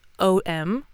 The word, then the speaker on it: Om: Because if you're going to see it spelled O-M a lot, it's actually composed of three sounds, four sounds if you count silence. Okay? So it is pronounced A-U-M. So om, which are three distinctive sounds.